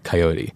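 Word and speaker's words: Caioti: But it's C-A-I-O-T-I. It's an Italian last name that's spelled Coyote.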